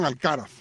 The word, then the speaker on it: Alcaraz: Número 1, dice que se pronuncia Alcaraz. ¿Estás seguro? Hoy me corrigieron y me dijeron Alcaraz.